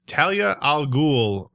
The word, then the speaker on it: Talia al Ghul: Talia al Ghul.